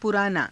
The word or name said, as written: puraanaa